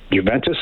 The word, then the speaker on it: Juventus: Every time I saw him, hotel, the Westin in Calgary down in Tampa, he was like, that was his lucky charm, was a Juventus, is that how you say it? – Yes.